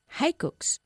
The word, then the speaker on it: hiccups: What's the best cure for hiccups? – ¿Cómo se pronuncia? Hiccups. – Hiccups. – No tiene nada que ver con la forma en que la escribimos esta palabra.